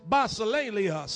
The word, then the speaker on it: basileia: The word kingdom in the Greek is basileia. And if you want to say it cool, kind of say it like the Greeks or the Italians, basileia.